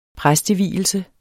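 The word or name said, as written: præstevielse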